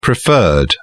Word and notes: preferred: British English

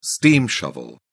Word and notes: steam shovel: British English

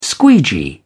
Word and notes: squeegee: British English

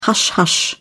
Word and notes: hush hush: British English

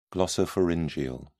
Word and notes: glossopharyngeal: British English

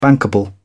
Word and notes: bankable: British English

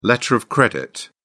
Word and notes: letter of credit: British English

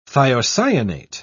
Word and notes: thiocyanate: British English

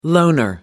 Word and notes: loner: American English

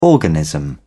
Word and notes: organism: British English

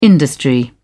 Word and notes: industry: British English

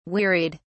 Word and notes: wearied: British English

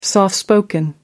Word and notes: soft spoken: American English

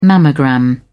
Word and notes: mammogram: British English

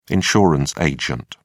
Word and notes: insurance agent: British English

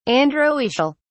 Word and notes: androecial: British English

